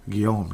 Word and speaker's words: Guillaume: Guillaume, just like Guillaume La Tendresse. That's how I know how to pronounce it.